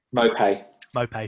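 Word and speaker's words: Maupay: Midfield of March, Bissouma, White and Lamptey, with Lallana and Trossard supporting Mopay, or however you say his name. – Mopay, thank you very much.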